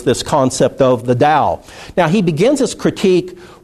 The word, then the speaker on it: Dao: Until recent years, when that word was translated into English, it was often spelled with a T, like Tao, but it's actually Tao, and now in most modern English writings, it's spelled D-A-O.